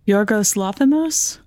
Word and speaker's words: Yorgos Lanthimos: I should know how to say this. I'm Greek. I don't think I'm saying it right. – I think you are. – Yorgos Lanthimos? – Yes, exactly.